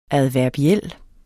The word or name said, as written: adverbiel